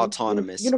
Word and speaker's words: autonomous: but like Montanious work. How do you pronounce that word? Like we're all okay, in uniform. – Okay. Autonomous. Okay. – Autonomous. Yeah.